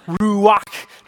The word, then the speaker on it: ruach: So a lot of times you'll see them translated breath, sometimes you'll see them translated spirit, but let me hear you say ruach. Ruach, yeah, if you're not getting the spit out, you're just not doing it well, it's ruach.